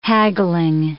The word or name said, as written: haggling